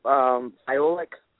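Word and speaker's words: Fiolek: I think you pronounce her last name Iolic.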